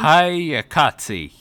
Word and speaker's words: Hayakatsu: So the Kados had, uh, I'm going to butcher this, Hayak, Hayakatsi? Hayakatsu? – Hayakatsu, yes.